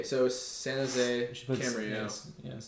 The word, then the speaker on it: Camarillo: She moved down, and I think she played Camarillo. – No. – Camarillo. – That should be our intro. – That's how it's pronounced. That's how it's pronounced, yeah.